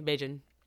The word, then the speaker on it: Beijing: Yeah, it's kind of like a soft hard J, Beijing.